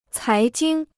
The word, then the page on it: 财经: cái jīng